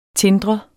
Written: tindrende

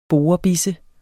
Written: borebisse

